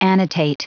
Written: annotate

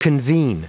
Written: convene

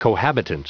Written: cohabitant

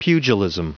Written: pugilism

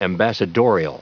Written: ambassadorial

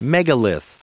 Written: megalith

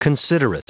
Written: considerate

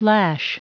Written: lash

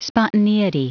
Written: spontaneity

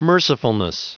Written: mercifulness

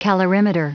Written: calorimeter

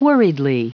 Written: worriedly

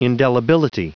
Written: indelibility

